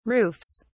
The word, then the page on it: roofs: British English